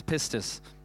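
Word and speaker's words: pistis (πίστις): Okay, it's called pistis. That's how you say it, it's pistis.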